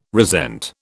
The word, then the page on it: resent: British English